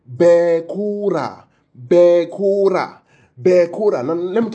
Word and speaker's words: bekora: Spell it for us. B-E-K-O-R-A-H. So it's bekora, bekora. Let me now show you something.